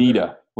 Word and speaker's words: Nita: That was Nina Brewing out of Ottawa that put that together. – Nita with a T. – Yes, Nita, yes.